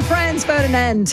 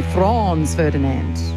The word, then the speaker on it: Franz Ferdinand: Glasgow band Franz Ferdinand, Take Me Out on Gold. Just great songs. Do you know the Americans have trouble saying that? They say Franz Ferdinand.